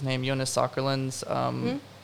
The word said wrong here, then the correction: Åkerlund: Jonas Åkerlund.